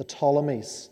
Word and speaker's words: Ptolemies: On the left hand side, you have the Ptolemies. So these are the rulers of Egypt, these are the pharaohs. They still use the name Pharaoh. The Ptolemies, spelt with a P.